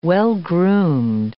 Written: well-groomed

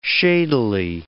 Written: shadily

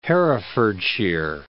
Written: Herefordshire

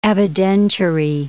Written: evidentiary

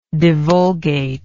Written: divulgatory

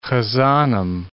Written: hazanim

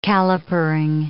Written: calipering

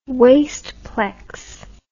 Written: wasteplex

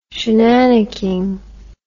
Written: shenannicking

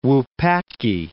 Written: Wupatki National Monument

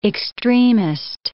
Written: extremist

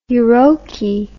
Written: euroky

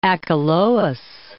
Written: Achelous

